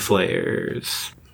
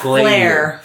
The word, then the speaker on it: flayer: Flayer, not flare. – Flayer. They flay.